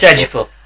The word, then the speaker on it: Ejiofor: It's Ejiofor.